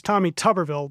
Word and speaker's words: Tommy Tuberville: It's Tommy Tuberville, by the way. I apologize for mispronouncing his name.